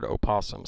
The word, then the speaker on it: opossum: But do you pronounce, right here it says, is the O in the word opossum silent? – No. – And it says the O is spelled but not pronounced.